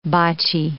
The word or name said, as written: botchy